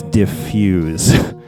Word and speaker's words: Dif Juz: diffuse i know it's spelled Dif Juz but i have it on very expert authority that it's diffuse